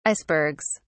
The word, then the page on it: icebergs: British English